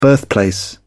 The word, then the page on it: birthplace: British English